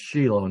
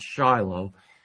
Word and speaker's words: Shiloh: Actually, the Hebrew word is pronounced Shiloh, not Shiloh, as it is in English.